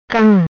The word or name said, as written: Cam